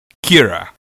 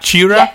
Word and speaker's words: Qi'ra: Kira. – Q-I-R-A? – Yeah, so it's pronounced Not Chira, because Q-I is Chi. That's the Chi standard. You know, the Qi wireless charging center is QI.